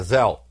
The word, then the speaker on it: Zelle: Ele chegou a falar de um sistema de pagamento americano, que ele até chamou Zelle, não é Zelle, é Zelle. Se escreve Z-E-L-L-E, mas é Zelle que se fala.